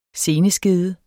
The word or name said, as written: seneskede